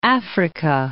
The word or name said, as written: Africa